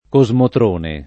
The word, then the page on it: cosmotrone: lingua italiana